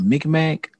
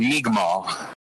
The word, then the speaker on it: Mi'kmaq: And I want to say it's called Micmac. It's pronounced Mi'kmaq.